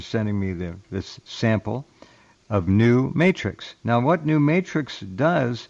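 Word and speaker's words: NuM8TRX: Max has another product called, I'll spell it, N-U-M, and then you look and you'll see the numeral 8, New Mate 8 TRX, New Matrix, New Matrix.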